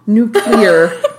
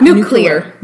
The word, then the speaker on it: nuclear: Well, there was kind of a nuclear panic. – Interesting. – Uh-huh. – Interesting. – No, I think I said it wrong. Nuclear panic, not nuclear.